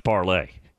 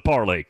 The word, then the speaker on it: Parler: P-A-R-L-E-R, pronounced parlay. According to Buckeye Brenda. Actually, I was looking at it over the weekend, and I went to a site, and it said it is pronounced parlay.